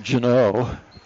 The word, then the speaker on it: Ganoe: You know, it's spelled G-A-N-O-E. And it looks like it should be pronounced Gano, but it's a hard G there. So we're pronouncing it Janoe.